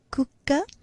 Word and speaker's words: kukka: The meaning is that the dog ran away, and the focus here on the pronunciation aspect is the double K in kukka. It's almost like a stress. You hold that sound slightly longer, and of course the pari,